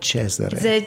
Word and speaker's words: Cesare: Your name spells in English Cesar. – In Italian, spell it Cesare.